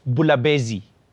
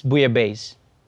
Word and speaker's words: Bouillabaisse: And by the way, it's not pronounced Bulabezi. It's Bouillabaisse.